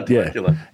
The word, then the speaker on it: Temecula: We were going to go stay in Temecula. Is that how you say it? – Yeah, Temecula.